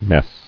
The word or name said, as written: Messed